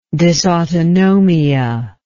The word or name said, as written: dysautonomia